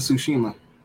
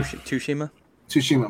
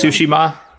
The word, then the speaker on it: Tsushima: It dropped same day as Ghost of Tsushima. – Yeah. – Tsushima, my bad. – Are we pronouncing it? – Yeah. Tsushima. Tsushima. Yeah.